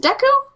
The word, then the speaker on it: Deku: Do you pronounce it Deku? – Deku, yeah, that's how I say it.